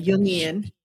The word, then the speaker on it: Jungian: It's pronounced Jung, like a Jung. Y, Jungian.